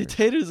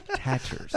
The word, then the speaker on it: tatters: How do you say it again? – Tatters. – I was going to say taters again. – Nope. Tatters.